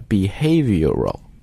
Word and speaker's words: behavioral: 那么这个 behavioral 它其实就是 behavior 这个单词来的，所以读音的时候一定要注意那个 v 一定要读出来 behavioral economics。